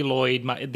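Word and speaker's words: Loyd: So as you said, I'm Tony Lloyd.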